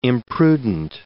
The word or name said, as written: imprudent